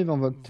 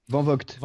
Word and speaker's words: van Vogt: von Gott. Ah oui, je connais Van Vogt. – Voilà. Van Vogt. Van Vogt.